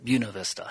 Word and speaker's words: Buena Vista: No, I went to Buena Vista University. It's now a university, but it was college. And some people will correct me and say Buena Vista, but actually everyone there calls it Buena Vista.